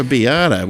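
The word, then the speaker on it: arrabbiata: How do you pronounce that? You're the most Italian of the three of us. Vodka arabiata. – Arabiata.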